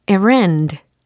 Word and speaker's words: errand: E R R A N D 라는 철자예요. 그래서 errand, errand 이렇게 발음을 합니다.